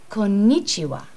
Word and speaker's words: Konnichiwa: Konnichiwa. – Konnichiwa. – Konnichiwa. Konnichiwa. Please repeat. – Konnichiwa.